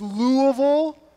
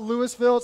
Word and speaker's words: Louisville: It's Louisville, okay? It's not Louisville. It's not Louisville. You say, what's it have to do with the Bible? Nothing. But you, that's just how you say it.